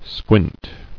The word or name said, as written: Squinted